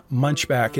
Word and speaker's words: Munchbach: And Munchback does not have a K on the end. It has an H, Munchbach. Munchback, Munchbach, with an H on the end. Say Munchbach. That's how my daughter pronounces it. I say Munchback. We all say Munchback in our family because we did not want to have a name that sounded like we were from Germany. So we said Munchback.